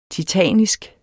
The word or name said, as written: titanisk